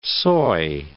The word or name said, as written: soy